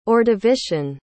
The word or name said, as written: ordovician